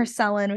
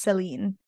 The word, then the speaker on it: Celine: Oh, I also, in the first book, they never specify this, but in this book, we learn how to pronounce Celine's name properly. And so we have been calling her Selin, which I think everyone calls her Selin because that's how it's written. But she's Turkish and it's apparently pronounced Celine because someone in the book, she like wrote out phonetically like C-E-L-I-N-E. Like someone was like Celine and she was like, oh yes, Celine. like that's my name.